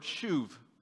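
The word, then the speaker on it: shuv (שוב): And it's the Hebrew word shuv, spelled S-H-U-B, but it's pronounced like shuv, right? Like I'm going to shove somebody, I'm going to shuv.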